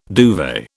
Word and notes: duvet: British English

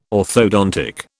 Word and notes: orthodontics: British English

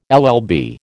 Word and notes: LL B: American English

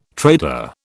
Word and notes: trader: British English

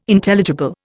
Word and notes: Intelligible: Indian

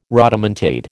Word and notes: rodomontade: American English

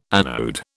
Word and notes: anode: British English

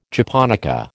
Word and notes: japonica: American English

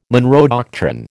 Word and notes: Monroe doctrine: American English

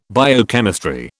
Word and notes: biochemistry: British English

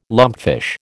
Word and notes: lumpfish: American English

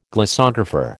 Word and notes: glossographer: American English

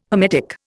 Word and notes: hermetic: Indian

